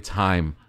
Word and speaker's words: Haim: She's like, "It's Haim." Yeah. It's good though, right? Like, I was so close. I knew the album, I knew the song, and I mispronounced the name by one syllable.